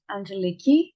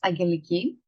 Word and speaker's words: Angeliki: And last but not least, this is Angeliki. I'm a Greek national. My name in Greek is pronounced as Angeliki.